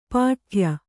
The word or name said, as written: ಪಟ್ಟೆಯ